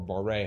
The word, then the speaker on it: barre: I was almost sure was bar or barre, however it's pronounced, B A R R E. – I think it's just bar.